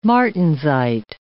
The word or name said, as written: martensite